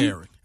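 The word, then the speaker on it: Caryn: And I'm not sure if it's pronounced Karen because it's got a C, but I presume it's Karen. – It's Karen. – Okay, it's a Karen.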